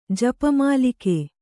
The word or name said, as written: ಜಪಮಾಲಿಕೆ